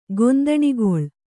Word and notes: ಗೊಂದಣಗೊಳ್: kannada

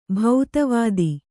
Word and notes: ಭೂತವತಿ: bhūtavati

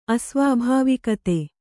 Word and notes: ಅಸ್ವಾಭಾವಿಕತೆ: asvābhāvikate

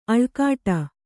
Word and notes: ಅಳ್ಕಾಡು: kannada

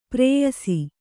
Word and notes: ಪ್ರೇಯಸಿ: prēyasi

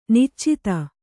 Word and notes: ನಿಚ್ಚೈದೆ: niccaide

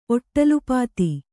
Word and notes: ಒಟ್ಟಲುಪಾತಿ: kannada

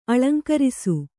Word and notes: ಅಳಂಕರಿಸು: aḷaŋkarisu

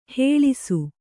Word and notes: ಹೇಳಿಸು: hēḷisu

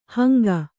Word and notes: ಹಾಂಗೆ: kannada

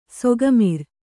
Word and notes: ಸೊಕ್ಕುಮುರಿ: sokku muri

